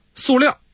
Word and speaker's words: 塑料: 说到这儿呢，还得补充几句啊，刚才你说塑料啊，到底是什么读音呢？反正现代汉语词典还有普通话这个标准考试上都读塑啊，塑料。